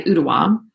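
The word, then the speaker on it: Ooltewah: I'm not even sure how to say it. It's spelled O-O-L-E-T-A-W. And I think the locals say Ootewa, Tennessee, which is outside of Chattanooga.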